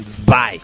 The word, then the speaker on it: Zwei: Bueno, pero está el Sway, el puto ese. – Sway. – Es que en el juego le dicen así como super alemán, Spy. Spy. Porque aparte se escribe z.w.e.i.